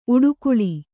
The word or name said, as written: ಊಡುಗೊಳ್